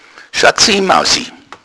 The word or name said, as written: mausi